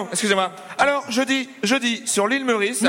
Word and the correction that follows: Maurice: Non, vous prononcez mal. C'est l'île Maurice.